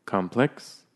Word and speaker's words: complex: So be careful with this one, because complex as an adjective has the emphasis on the plex. Complex. But if you put the emphasis on the first syllable, it becomes a noun. If you say complex, it means something totally different.